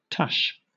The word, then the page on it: tush: British English